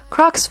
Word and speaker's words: Crocs: Crocs 发音跟 cross 的发音很像啊，十字架。只要把 cross 第一个 s 改成 c 啊，第一个 s 改成 c，发 k 的音就可以了。 Crocs，还有一个是 UGG 啊，这是一个鞋子品牌，他们最有名的就是毛绒的靴子，现在也变成了毛绒靴子的总称。